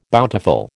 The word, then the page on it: bountiful: American English